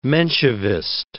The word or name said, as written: Menshevist